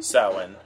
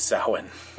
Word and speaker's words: Samhain: Samhain? – Samhain. It's spelled Samhain. But because the Celts had this vendetta with spelling words in any sort of way, how they are written or sound, it's Samhain.